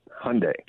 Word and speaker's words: Hyundai: It's interesting you said that, because when I think of Hyundai, did I say that right, Hyundai or Hyundai? – Hyundai. – Hyundai like Sunday.